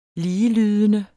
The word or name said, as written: ligelydende